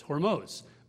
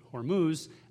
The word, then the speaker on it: Hormuz: And so my name is the right way to say it is Hormuz. But for whatever reason, when it got on the maps, people started pronouncing it Hormuz.